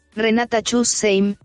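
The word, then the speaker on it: Renata Schussheim: Esto lo dijo una artista hecha y derecha que se llama Renata Skushenhem. Y si no lo pronuncié bien, por favor, ayúdanos. – Se pronuncia Renata Chusheim, así se pronuncia jazz. – Gracias, exacto, así se pronuncia.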